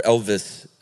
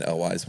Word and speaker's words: Elwes: And uh, there were three gentlemen uh, named Chris Sarandon, Kelly, uh, Cary Elwise, or Elvis apparently is how you really pronounce it. – Really? – Yeah, I've been saying Elwes my whole life.